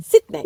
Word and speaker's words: Sydney: sondern es ist ein I. – Sydney. – Sydney. – Ja. Mehr ein I, mehr die Ja. – Betonung auf einem längeren I.